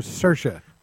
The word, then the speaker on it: Saoirse: Saoirse Ronan from the... – The Lovely Bones. – The Lovely Bones. Who her name is spelled nothing like how it's pronounced. – It's because she's Irish. And Saoirse.